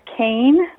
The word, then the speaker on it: Kean: Yeah, I mean, if people want to contact me, I have a website, and it's Surviving Death Kane. So it's the title of my book and my last name, K-E-A-N, is how you spell it, yes.